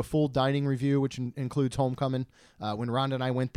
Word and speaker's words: Homecoming: Well pronounced. No G on the end. – I know. – It's Homecoming.